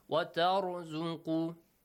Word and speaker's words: وَتَرْزُقُ: The letter ra has to be pronounced with a heavy sound. وَتَرْزُقُ مَن تَشَاءُ بِغَيْرِ حِسَابٍ.